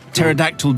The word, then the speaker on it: pterodactyl: Why can't you hear a pterodactyl dinosaur go to the toilet? Because it has a silent P? Because it's written P pterodactyl.